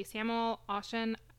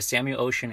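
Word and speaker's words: Samuel Oschin: So you do develop some exhibits for the Samuel Ocean, I'm sure I did not say that right, Air and Space Center. How should I say that? – That's okay. It's the Samuel Ocean Air and Space Center.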